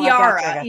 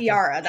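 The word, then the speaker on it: Eira: So I'm trying to remember how they say that name. Oh, Iara. Iara. That's how they say it.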